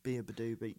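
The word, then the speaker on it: Beabadoobee: Yeah, and I didn't know that song before it, and it got me into Be a Badoobie. So thank you, Heartstopper. – I didn't know that's how you pronounced their name. – Didn't you? – Be a Badoobie.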